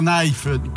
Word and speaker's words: knife: Super Knife. Knife, comme oui, disent les Français, un couteau. Mais on prononce pas le K.